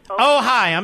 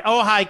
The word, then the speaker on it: Ojai: Liz, is it Ojai, California? Is that how you pronounce it? Ojai, I'm sorry. Ojai, California, 870 The Answer.